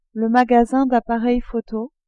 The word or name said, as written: magasin d'appareils photo